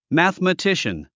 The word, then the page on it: mathematician: mæ`θəmətíʃən; マスマティシャン